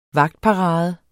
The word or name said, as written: vagtparade